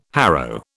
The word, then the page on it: harrow: British English